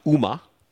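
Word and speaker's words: Uma (馬): The word for horse in Japanese is Uma, just pronounced just like Uma Thurman.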